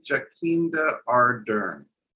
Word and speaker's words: Jacinda Ardern: So speaking of horse-faced trannies, Jacinda Ardern, how do you say your prime minister's name? Jacinda Ardern.